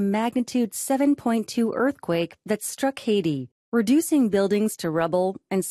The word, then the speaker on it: Haiti: 영어로는 Haiti라고 읽고요. 우리말로는 IT라고 읽는 나라가 되겠습니다.